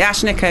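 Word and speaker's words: Ashnikko: Now, I pronounced that right, didn't I? – I think you did. Ash Nico, yeah.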